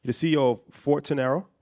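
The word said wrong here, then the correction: Forterro: Fortero, your partner in Courtin Capital, and you're chairman in FFG.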